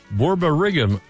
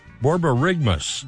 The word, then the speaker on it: borborygmus: Finally, the scientific word for your stomach rumbling is pronounced borborygmus. – Borborygmus.